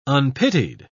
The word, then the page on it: unpitied: British English